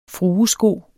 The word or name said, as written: fruesko